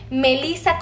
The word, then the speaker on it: Melissa: Y de igual manera me van a encontrar con mi nombre, Melisa con doble S, Mi apellido se escribe con T de Tito, con J de jamón y con la letra de dedo.